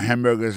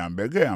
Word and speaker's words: hamburger: N'a pas remarqué que dans le mot hamburger, ça, comme tu as dit, il y a trois syllabes là-dedans, côté appui et puis plus ça, c'est sous premier syllabe là.